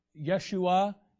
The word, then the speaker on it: Yeshua: Yeshua is how Jesus' name is pronounced in the Hebrew.